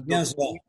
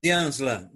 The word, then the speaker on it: Dianslan: Como, como se pronuncia? – Dianslan. Dianzlan, Doutor Daniel Dianslan.